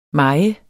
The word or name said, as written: maj